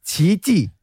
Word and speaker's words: Qiqi: It's Qiqi. – Qiqi. – As in, it's the Chinese character that is Qi. So I'm guessing that it's Qiqi.